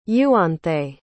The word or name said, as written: euanthe